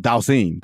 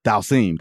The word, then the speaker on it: Dhalsim: I can't pronounce his character's name. From Dhalsim. That's what it was. Dhalsim. That's how you pronounce his name.